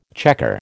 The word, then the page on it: chequer: American English